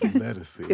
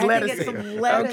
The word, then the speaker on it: Ledisi: I never know how to pronounce her name. – It's Ledisi. It's Ledisi. Okay, all right, it's Ledisi, who some people may know as an R&B singer.